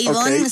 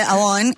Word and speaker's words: Avon: No se dice Avon, se dice Avon.